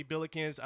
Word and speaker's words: Billikens: Billikens, did I pronounce that right? – Yeah, Billikens? – Got it.